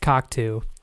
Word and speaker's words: Cocteau: C-O-C-T-A-U, and it's pronounced Cocteau.